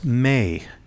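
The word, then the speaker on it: may (μή): And the Greek language used there begins with a little 2-letter word that's pronounced may.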